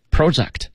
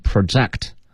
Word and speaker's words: project: 那么这个 project，我们学过它的名字叫 project，项目，project。那么做动词的时候呢，一般读成 project，啊，project，动词，什么意思呢？